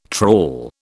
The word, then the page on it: trawl: British English